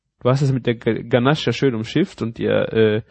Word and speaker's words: Ganache: Ich bin heute die Ganache, oder? – Ganache. Das war ja ein Versprecher, das war ja nicht falsch ausgesprochen.